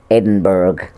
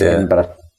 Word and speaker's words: Edinburgh: Because the Yanks usually say Edinburgh. Right. It's not Edinburgh, it's Edinburgh.